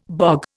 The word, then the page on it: bog: Indian